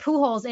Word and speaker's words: Pujols: And for Albert Pujols, it literally says Poo hyphen holes, like P-O-O and then holes in the parentheses for how to pronounce Albert Pujols' name,